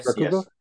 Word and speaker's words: Rakugo: Is it Rakugo? How do you pronounce Rakugo? Rakugo? Yes.